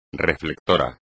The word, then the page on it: reflector: espagnol